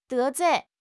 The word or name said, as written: 得罪